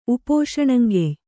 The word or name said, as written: ಉಪೋಷಣಂಗೆಯ್